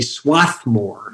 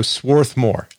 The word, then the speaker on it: Swarthmore: Swarthmore. There's an R in there that people tend not to say. They say Swarthmore. Mm. Drives the people associated with the school a bit crazy. – So Swarthmore.